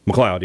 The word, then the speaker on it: McLeod: Well, that's Christian McLeod. I'm saying that right, right? – McLeod, yeah.